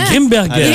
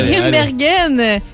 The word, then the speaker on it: Grimbergen: La Grimbergen. – La Grimbergen.